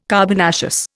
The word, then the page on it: carbonaceous: Indian